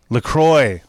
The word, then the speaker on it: LaCroix: Is that that's a Le Creux? How do you say that? Le Creux? LaCroix. That's right.